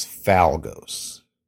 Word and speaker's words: Falgos: And his name in Machine Robo, and I think I'm saying this right, was Falgos. Falgos or Falgos. It's F-A-L-G-O-S.